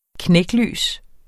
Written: knæklys

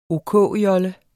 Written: OK-jolle

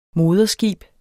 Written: moderskib

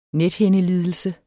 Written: nethindelidelse

